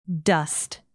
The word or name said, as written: dust